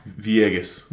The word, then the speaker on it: Villegas: Villegas.